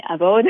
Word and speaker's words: Avowed: mais Avowed.